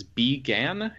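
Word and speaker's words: Bi Gan: Okay, so picking up from my airplane story from earlier, I watched Long Day's Journey into Night, which is directed by, I think his name is B. Gan, is how you pronounce it.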